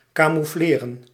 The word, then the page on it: camoufleren: /kɑmuflerən/